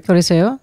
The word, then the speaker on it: Coliseo: Coliseo.